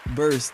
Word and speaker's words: Burse: My name is Zeke Burse. That's spelled Z-E-E-K. B as in boy, U-R-S-E. If you're in London, it's Z-E-E-K. B-U-R-S-E.